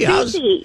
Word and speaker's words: Soucy: Soucy, yeah.